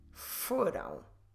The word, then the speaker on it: foram: F-O-R-A-M. Foram. Don't forget that we should stress, we must stress the first syllable. It's the second to last, but in this case we only have two, so it's also the first.